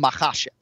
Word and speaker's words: Makhachev: I'm focused on if you emphasize the syllable correctly. So what you will hear on broadcast Saturday night, I will say Islam Makhachev, right? So TJ technically emphasized the wrong syllable in the last name, but because the file is open to interpretation, I'm going to give him the point.